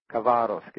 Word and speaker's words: Khabarovsk: Khabarovsk is how that's pronounced in Russian.